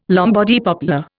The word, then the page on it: Lombardy poplar: Indian